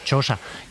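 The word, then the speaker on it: Chioggia: Gioia, no sé pronunciarlo. – Gioia. Gioia, como dicen ellos, Chosa, que está allá fuera, que es una especie de pequeña Venecia, pero esto ya está, digamos, es una peninsulilla.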